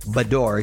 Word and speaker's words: Bedore: After 17 years of marriage, Shannon and David Bedore, did I pronounce that right? – Bedore, excuse me. – It's like a bidet. – Yeah. – But a Bedore.